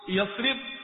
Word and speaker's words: یثرب: اول تو یہ یثرب تلفظ غلط ہے، اصل یثریب،